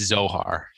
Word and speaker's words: Zohar: That's what it sounds like at first, but it is, they correct it later. Mikey Workray said it right. – You don't mess with the Zohar. – Yeah, the Zohar. Exactly. It's Zohar. So I thought it was Noha first.